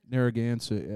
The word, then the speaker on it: Narragansett: Narragansett? – Narragansett, yeah.